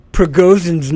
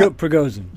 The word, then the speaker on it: Prigozhin: Speaking of, I want to get Prigozhin's name right.